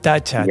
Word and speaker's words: Tatcha: Hi, we're here with Daniel Martin, makeup artist and also a global director of artistry and education at Tatcha.